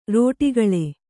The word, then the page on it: ರೋಟಿಗಳೆ: kannada